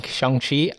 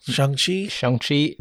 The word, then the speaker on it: Shang-Chi: And of course, it's starring Simu Liu playing Shang-Chi. I think is how you pronounce it. – Shang-Chi. – They say it's like song, not sang, is the way you're supposed Shang-Chi? – To.